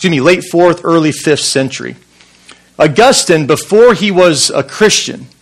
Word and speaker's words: Augustine: I want to begin this morning with a brief story about Augustine, or maybe you've heard it pronounced Augustine, one of the greatest theologians in church history.